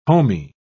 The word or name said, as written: homey